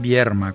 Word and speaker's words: Vierma: Aclaro que no estoy pronunciando mal, es Vierma con R.